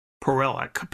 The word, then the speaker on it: Paralic: Speaking of songwriters, the duo that we featured before that are Bob Levy and Drew Paralic, P-A-R-A-L-I-C, Paralic, I guess is how you say his name.